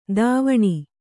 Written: ಧಾವನಿ

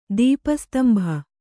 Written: ದೀಪಸ್ತಂಭ

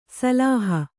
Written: ಸಿಲ್ಹ

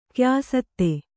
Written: ಕ್ಯಾಸತ್ತೆ

